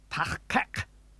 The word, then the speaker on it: Tach-kek: Tach-kek. There's a new sound at both the beginning and end of the second syllable, kek. Be careful. This is not the Federation standard K sound.